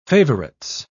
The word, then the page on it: favourites: British English